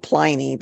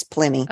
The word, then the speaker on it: Pliny: It's spelled Pliny, but I think it's Pliny.